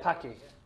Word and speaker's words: Pocky: How do you pronounce this, though? Is it the Pocky? Pocky? Pocky? – Pocky. – Pocky. – It's Pocky. He loves that.